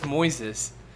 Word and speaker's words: Moise: His name was Moises. Moise. It was M-O-I-S-E.